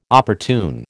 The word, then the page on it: opportune: American English